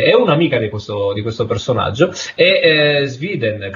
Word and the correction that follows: Schroeder: credo che si pronunci. – Schröder. – Come, scusami? – Schröder. – Schroeder, non Sweden.